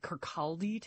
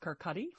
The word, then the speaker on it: Kirkcaldy: Looks like Kirkcaldy to us, but I recently learned that it's pronounced Kirkcaldy.